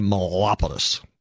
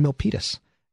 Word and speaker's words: Milpitas: Trust me, if you ever want to hear me mispronounce something, put a biotech company in front of me because I'll say Melopolis. Melopolis. Does anyone know what Melopolis is? It's my way of saying Milpitas.